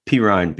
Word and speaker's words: Perine: but to pick up Perine, how do you say Perine? – Perine. Almost sounds like I'm saying it wrong by saying it right.